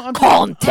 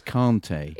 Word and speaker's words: Kanté: No, don't be like those people that just say everything in their native accent, but they insist on going Conte every time they pronounce N'Golo Kanté's name on commentary. – It's not, it's Kanté. – It just does my head in. – No, but it isn't Conte. It's Kanté.